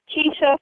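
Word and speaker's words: Kesha: Oh, well, my website is Keisha for Congress. That's K-E-S-H-A. Everyone says I spelled my name wrong, but anyway, no, it's K-E-S-H-A for Congress.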